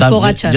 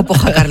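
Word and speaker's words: Pogačar: Un amigo nos dice también por WhatsApp que pronunciéis por favor bien el nombre de Pogacar. – Pogacar, ah, ya. Es verdad, es Pogacar. – Yo también lo tenía ahí. – Es que yo siempre le digo Pojacar, lo siento.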